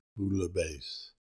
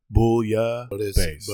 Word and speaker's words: bouillabaisse: Bola Basil? – Bouillabaisse. – Anyway. – Like booyah without the ah. Bouillabaisse.